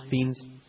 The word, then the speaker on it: Fiennes: Fiennes.